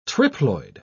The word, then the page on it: triploid: British English